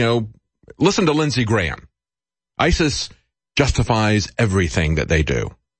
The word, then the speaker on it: ISIS: I want to talk to Wayne about ISIS. You know, it rhymes with crisis.